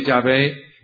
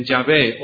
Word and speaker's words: Yahvé: Sea que pongamos H en Yahvé o la quitemos, no afecta a la pronunciación.